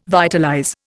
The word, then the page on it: vitalize: Indian